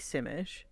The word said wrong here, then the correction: Simlish: Oh, did I say that wrong? – Sim, I think it's Simlish. I I've never played this.